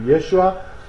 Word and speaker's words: Yeshua: Sometimes they call him Yeshua in this text, simply because it seems that the Aramaic pronunciation would have been close to that to Yeshua.